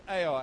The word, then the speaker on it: Ai: A little while later, after the armies rested and refreshed, the next town that they decide to conquer is a little place called Ai. And that's how it's spelled, A-I. Ai.